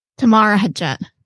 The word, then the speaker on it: Temara Hajjat: I said it right, right? – Temara Hajjat.